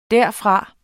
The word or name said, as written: derfra